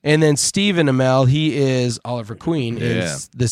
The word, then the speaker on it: Amell: Anyway, a couple of cousins, Robbie Amell, is that how you pronounce it, Dave? You know all these guys. – Amell, yeah.